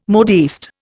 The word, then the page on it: modiste: Indian